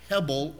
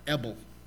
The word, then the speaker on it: hebel: And it's the equivalent then of the word that is the actual Hebrew word here, and it's hebel, or if you want to make the H, which is many times in Hebrew, it is silent, ebel. You have a silent H on the beginning of the word, ebel or hebel.